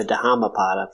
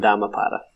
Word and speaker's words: Dhammapada: I'm with Amanda on this one, but I do like the pronounced H, the Dhammapada, but I was doing Dhammapada. – Dhammapada seems and feels right to me. As always, the way I pronounce it does not feel right, so I'm going to back away quickly. I prefer the way you guys have said it. – I only go with the silent H because of the Buddha.